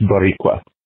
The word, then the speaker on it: Boricua: It's pronounced Boricua. Huh? Boricua. That's what Puerto Ricans call themselves.